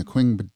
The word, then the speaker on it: Qing: So in 1895, the island came under full Chinese control during the Qing Dynasty. It's Q-I-N-G. I'm not sure I'm pronouncing that properly, but that's how I would say it. – That works.